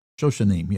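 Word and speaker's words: Shoshanim (שושנים): How do you say that? Shoshanim. Shoshanim? Shoshanim, yeah.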